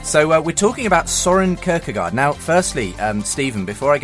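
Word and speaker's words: Søren Kierkegaard: And whenever there is, they tell me it's Soren Kierkegaard.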